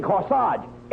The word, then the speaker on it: corsage: Oh, Abbott, I bought her the most beautiful corsage. – That's swell. Not corsage. You mean corsage? – You mean corsage. A-G-E is pronounced arge, as in corsage and garage.